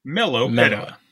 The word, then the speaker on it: Meloetta: I forget how to pronounce that. I'm gonna be extra today. – Meloetta. – That's how you say it.